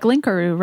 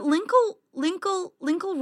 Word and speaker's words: Linkle: It's just, although in Japanese, I guess it is pronounced like Linkaru, right? Like, it's not pronounced the same way, which doesn't sound as bad. – But Linkle rhymes with tinkle.